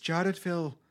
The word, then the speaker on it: Jadotville: I know I'm pronouncing it wrong. The Irish people would be like, it's Jadotville.